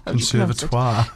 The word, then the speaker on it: Conservatoire: Is it conservatory? Conservatoire? – Conservatoire.